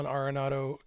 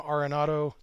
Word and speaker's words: Arenado: And once again, my goal is to get through this whole episode pronouncing his last name Arenado the way it's apparently supposed to be pronounced instead of Arenado the way I always have.